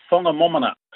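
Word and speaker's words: Whangamomona: It took me ages to realise how to pronounce it properly, actually. It's the Whangamamona Hotel, and that's in Taranaki on the Forgotten World Highway between Stratford and Tamaranui.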